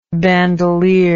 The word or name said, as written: bandoliered